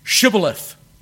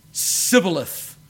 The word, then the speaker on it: Shibboleth: And the Gileadites said to them, say, Shibboleth. And they said, Sibboleth, because they couldn't frame to pronounce it right.